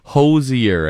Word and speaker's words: Hozier: No, it is Hozier, as in his hose is hosier than your hose.